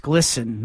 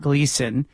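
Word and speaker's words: Glisan: I have heard that as well, that it's Glisten, not Gleason.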